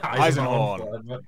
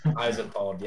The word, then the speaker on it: Eisenhorn: as well as I'm going to butcher his name now, even though it's just been said, Esselhorn. – Eisenhorn. – Eisenhorn, yes.